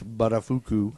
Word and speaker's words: Buttafuoco: Pronounced Buttafuoco. – Buttafuoco. – Buttafuoco, sorry. – Not Buttafuoco.